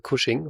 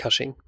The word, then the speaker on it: Cushing: Er heißt natürlich Peter Cushing und nicht Cushing.